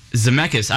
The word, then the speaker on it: Zemeckis: Oh, Robert Zemeckis. – Zemeckis. I've been saying his... Oh, shoot. I've been saying it wrong the entire time.